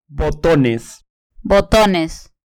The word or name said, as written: botones